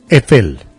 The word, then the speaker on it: Eiffel: La Torre Eiffel fue... – A mí me pronuncia bien la Torre. La Torre me la pronuncia bien. – ¿Cómo es que es? – Eiffel.